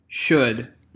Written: should

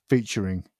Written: featuring

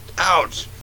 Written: ouch

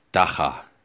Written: dagga

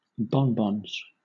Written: bonbons

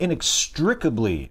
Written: inextricably

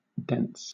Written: dents

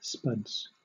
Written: spuds

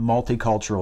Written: multicultural

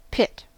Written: pit

